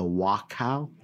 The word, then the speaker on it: Wachau: Katja Scharnagl hails from the Wachau, is that how I pronounce it? – Yes.